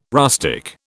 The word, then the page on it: rustic: British English